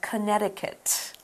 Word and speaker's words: Connecticut: It's not said how it's spelt. It's spelt...Connecticut, uh-huh. – but it said Connecticut. – Connecticut. – Mm-hmm. – Ah. – But it's spelled Connecticut. – Right. – Don't say that second C.